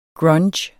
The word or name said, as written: grunge